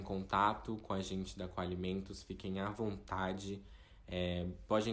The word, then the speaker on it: Qualimentos: Qualimentos a gente escreve bem é, como se fala mesmo, então QU Alimentos, tudo junto com Alimentos e o JR de Júnior.